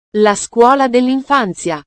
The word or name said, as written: la scuolla dellinfanzia